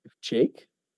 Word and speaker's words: Jake: My Italian family still calls me Jack. They can't pronounce Jake for some reason, so it is what it is.